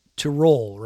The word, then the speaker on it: Tyrol: And it's a region called Tyrol, right? Am I pronouncing it correct? – Yep.